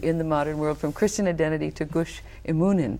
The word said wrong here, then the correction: Emunim: Did I say that right? – Emunim. – Thank you.